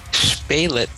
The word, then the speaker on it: Spelic: It's Spelic.